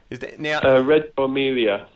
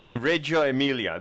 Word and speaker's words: Reggio Emilia: I'm probably not going to pronounce this properly, which is sad because I'm Italian, but Reggio Emilia, Reggio Emilia. – Reggio Emilia, there you go.